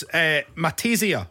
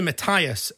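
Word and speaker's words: Mattias: How to pronounce Matesia? Remember, we heard that last week. – Who? – Matesia. – Oh, from Sweden. – From Sweden. It's actually Matthias, and the A is just his second name on the end.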